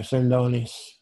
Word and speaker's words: Sindoni: Sindoni, okay. Like Ronzoni with an S. – okay, Sindoni.